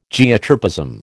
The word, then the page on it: Geotropism: American English